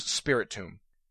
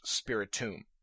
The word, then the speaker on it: Spiritomb: The last Pokemon I want to talk about is Spiritomb. I've heard people saying Spiritom. It's not spelled that way. It's the word spirit and tomb put together. So clearly the name is pronounced Spiritomb.